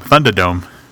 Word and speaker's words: Thundadome: How do you pronounce that again? – Oh, it's Thundadome. Thundadome.